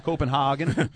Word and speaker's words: Copenhagen: Copenhagen.